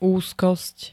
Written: úzkosť

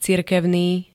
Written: cirkevný

